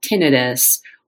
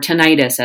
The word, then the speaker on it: tinnitus: Today I want to talk about tinnitus, or tinnitus as it's sometimes pronounced.